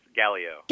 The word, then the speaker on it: Galio: I think a Galio. I think I pronounced his name correctly. – Close. Galio.